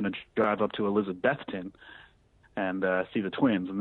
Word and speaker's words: Elizabethton: I'd always said Elizabethton when pronouncing the name of the Twins, just kind of Elizabethton. But I've gotten corrected a few times with Elizabethton. You really, when you start to say that word, think of Beth, the name Beth.